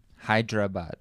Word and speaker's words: Hyderabad: Hyderabad.